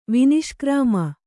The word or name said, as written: ವಿನಿಷ್ಕ್ರಾಮ